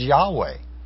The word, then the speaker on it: Yahweh: They would not say what we believe now is best pronounced as Yahweh.